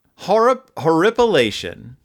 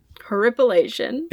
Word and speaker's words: horripilation: I-P-I-L-A-T-I-O-N. – Horripilation. – Horripilation. – Is a noun. It is the erection of hairs on the skin due to cold, fear, or excitement. – That's not a real word. – Horripilation.